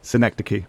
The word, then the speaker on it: Synecdoche: Synecdoche.